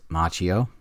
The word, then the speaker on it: Macchio: It's Macchio and it's Miyagi. So anybody that's watched the movie several times, you know that you know that's the way that those are pronounced.